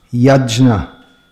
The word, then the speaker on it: Yajna: Apparently this should be properly pronounced Yajna. Different ways to pronounce this. But in modern Indian languages, the J-N is always pronounced Gya.